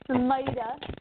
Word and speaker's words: Smita: The Super Console Smita. The I is a long I.